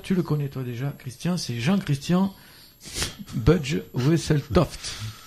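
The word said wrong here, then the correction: Bugge: C'est prononcé n'importe comment. Bug. – Bug, oui. Voilà.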